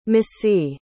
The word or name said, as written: misi